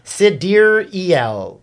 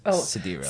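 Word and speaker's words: Sideræl: It's like Musk, Exa, and Dark are easy pronunciations, but Mother of Newborn, Exa, Dark, Sidera Musk shared that the correct phonetic pronunciation of the daughter's name is Sidereal. Actually, you could do that better. – Sidereal? – Why? – Sidereal.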